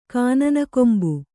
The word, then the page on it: ಕಾನನಕೊಂಬು: kānanakombu